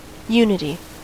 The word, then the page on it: unity: American English